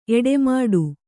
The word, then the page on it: ಎಡೆಮಾಡು: eḍemāḍu